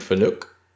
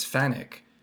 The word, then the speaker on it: Fanuc: Saunders heard the tech guy say it's Fanuc, but it's a Japanese company. And if you pronounce it in Japanese, it's not, it's Fanuc.